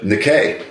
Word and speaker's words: Nike: And I said, hey, there's Nike, our school mascot. And the guide says, typical American, pronouncing it wrong. – How did he pronounce it? – Nikkei. Nikkei. Huh.